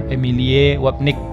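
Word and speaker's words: Emilie Wapnick: Nah, kali ini disampaikan oleh Emily Wapnik. atau si bacanya Emilie Wapnik cari aja di YouTube ada tuh Emilie W A P N I C K itu ada di YouTube.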